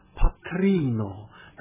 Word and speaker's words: patrino: Und die Mutter heißt dann Patrino. Da wandert dann auch wieder die Betonung auf die vorletzte Silbe.